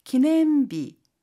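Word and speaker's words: 기념비 (記念日): 기념비. 기념일이라고 하는 한자어인데요. 마찬가지로 요 일을 비라고 발음합니다.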